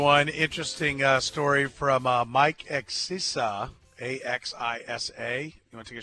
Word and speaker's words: Axisa: It's Access, so we've had him on the show before.